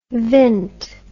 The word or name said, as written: vint